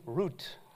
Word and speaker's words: Ruth: I mean, obviously, I don't need to say, oh, in Hebrew you say Ruth, Root.